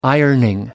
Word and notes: ironing: American English

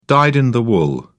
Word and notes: dyed in the wool: British English

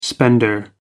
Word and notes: spender: American English

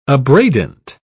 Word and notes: abradant: British English

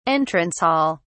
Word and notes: entrance hall: British English

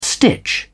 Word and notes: stitch: British English

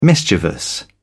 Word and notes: mischievous: British English

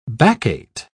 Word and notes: baccate: British English

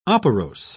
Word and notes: operose: British English

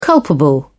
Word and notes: culpable: British English

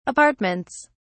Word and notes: apartments: British English

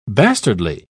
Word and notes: bastardly: British English

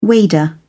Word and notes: wader: British English

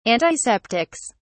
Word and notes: antiseptics: British English

